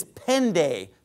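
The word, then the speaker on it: pende (πέντε): So the Greek number 5 is pende, P-E-N-T-E. Pende is how you pronounce it in modern Greek.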